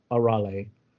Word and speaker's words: Arale: It's Arale.